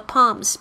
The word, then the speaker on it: palms: Press your palms，这个 palms P A L M，但是这个 L 没有发音呢。